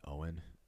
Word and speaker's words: Oin: uh, I think it's technically pronounced Oin.